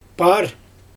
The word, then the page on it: paar: Xyzyl